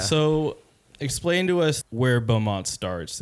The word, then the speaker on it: Baudmont: So let's dive into Baudmont now.